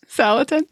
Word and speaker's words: Salatin: This is so much fun and just makes me want to pick up a bunch of books by Joel Salatin. Is that how you say it? – Yes. – Salatin.